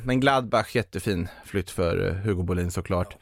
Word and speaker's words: Hugo Bolin: Jag insåg att jag uttalade liksom spanskt Hugo Bolin. Hugo Bolin till Borussia Mönchengladbach på ett lån med köpoption.